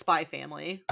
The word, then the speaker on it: Spy x Family: Well, you don't pronounce the X, it's just Spy Family.